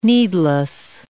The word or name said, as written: needless